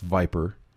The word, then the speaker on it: VIPR: or VIPR, pronounced VIPER,